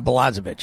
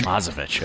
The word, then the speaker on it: Blazevic: Have we confirmed the pronunciation of Jordan B's last name? – Yeah, Blazevic. – Blazevic. – He said, although his Czech relatives put a lot more emphasis on the N there and, you know, have a lot more lilting, but he goes with Blazevic.